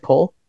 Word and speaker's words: Coll: C-O-L-L. Is that Cole? Call? – That sounds like Cole.